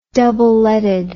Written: double-leaded

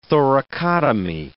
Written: thoracotomy

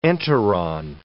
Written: enteron

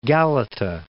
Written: Galata

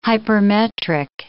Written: hypermetric

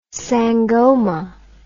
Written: sangoma

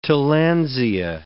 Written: tillandsia

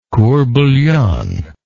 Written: court bouillon

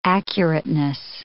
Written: accurateness

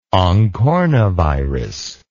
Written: oncornavirus

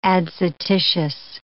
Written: adscititious

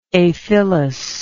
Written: aphyllous

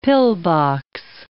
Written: pillbox

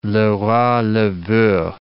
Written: le roi le veut

